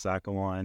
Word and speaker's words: XACML: But for our services on our floor, we're using a Zacamal engine, open source Zacamal engine. I don't know how you actually pronounce that. X-A-C-L. – Yeah, X-A-C-M-L. Zacamal.